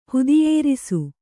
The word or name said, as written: ಹುದಿಯೇರಿಸು